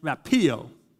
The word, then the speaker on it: rapio: It's pronounced as rapio. Rapio. It's the same equivalent to the Greek word harpazo, which means to be taken away, to be snatched, to be rescued.